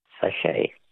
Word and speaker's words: sachet: Words that end with a silent T. Which word for a small sealed pouch literally means little sack in French? – Sachet. – A sachet. S-A-C-H-E-T.